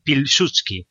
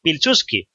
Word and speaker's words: Piłsudski: Por ejemplo, que no se dice Piłsudski, porque la L de Piłsudski es una L tachada. Y las L tachadas en polaco se pronuncian como una W, porque en polaco parece ser que los polacos piensan que no tienen suficientes W en su idioma y tienen que meter más.